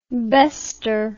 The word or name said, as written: bester